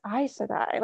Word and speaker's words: Aes Sedai: So my daughter, for example, said something really funny a while back where she's like, "Mommy, it's not Aes Sedai, it's Aes Sedai."